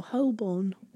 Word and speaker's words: Holborn: Americans try and spell it. – Horborn. – There's a silent L. – Oh, Holborn.